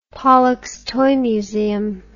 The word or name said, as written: Pollock's Toy Museum